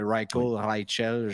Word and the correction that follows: Reichel: je n'ai pas exactement regardé sa prononciation. – C'est Reichel.